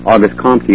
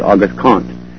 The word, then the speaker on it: Auguste Comte: Auguste Comte is actually Auguste Comte.